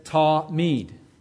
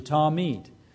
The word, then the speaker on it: Tamid: But there you have the Hebrew, and then you have the equivalent English letters, and then you have the pronunciation, Tamid. Tamid. That's how you say the word, Tamid.